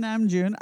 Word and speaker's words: Namjoon: He is Kim Namjoon.